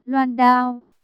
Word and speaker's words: loan đao: Cái chữ này đọc là, loan đao. Hổ Vân Kiều đột nhiên nghe được nha đầu niệm sai một chữ, lập tức mở miệng sửa lại.